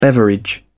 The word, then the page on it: beverage: British English